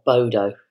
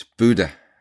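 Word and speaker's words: Bodø: Listen, I'm no expert, and I'm sure I'm saying it wrong, but it's not Bodo. – It's Buda. Buda.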